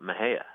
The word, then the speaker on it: Mexia: We have a funny town here called Mejia, and it's spelled M-E-X-I-A. And I think if you're an out-of-towner or a Yankee, you might be tempted to say Mexia, right? Or some other. But townspeople call it Mejia.